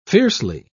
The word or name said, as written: fiercely